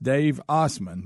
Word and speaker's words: Assman: His name is pronounced Dave Osman, but it's spelled Dave Asman. – Greg? – Okay. Asman. – So it's spelled A-S-S-M-A-N.